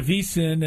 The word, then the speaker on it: VSIN: Is it VSIN or VI, VSIN, Stormy? I want to. It's V. Yeah, VSIN. You said it right. VSIN. – Yeah, VSIN.